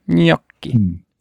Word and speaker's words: Gnocchi: Gnocchi.